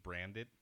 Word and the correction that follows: Brandt: Brandt. T-silent.